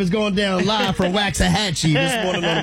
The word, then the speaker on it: Waxahachie: Waxahachie? – Waxahachie. Waxahachie.